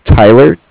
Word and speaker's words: Tyler: My name is Tyler, T-Y-L-E-R. – Okay. – And I'm calling with Cardiff. It's spelled C-A-R-D-I-F-F, as in Frank.